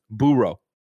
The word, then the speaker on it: burro: Ignacio Rojas writes in and says, burro is pronounced burro. – It's Spanish. That's what I said. Donkey, I said burro. – I mean, he's correcting me, not you. Not you. Because I was saying burro like a dog burrows.